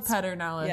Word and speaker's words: Pedernales: Because Krause Springs, that looks like it would be K-R-A-U-S-E, Krause, but it's Krause. – Krause. – Because it's Texas. – And Pedernales. – Yeah, it's not. – It's Pedernales. – Pedernales. – But yeah. it's spelled Pedernales. Yeah. – Pronounced Pedernales. – Pedernales. I mean, LBJ, he said it.